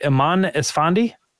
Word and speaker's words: Iman Esfandi: Is it Iman Esfandi? – Yes.